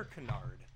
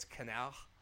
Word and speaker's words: canard: It's French, so apparently it's canard.